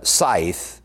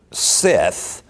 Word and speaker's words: scythe: Scythe, S-C-Y-T-H-E, right? Which is used for harvesting. And in my head, I always pronounce that word Sith. Was what? Yeah, Sith. But it is not the Dark Lord from Star Wars. Yeah. Which is S-I-T-H. Scythe.